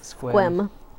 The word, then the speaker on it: Sequim: But there was that, like, Sequim town that ended up being pronounced Squim. – Squim. That was way off. Who would think Squim?